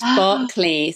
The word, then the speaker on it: Berkeley: Berkeley is pronounced Berkeley. So it's actually like an English thing. So it's the Americans, as usual, who pronounce it wrong.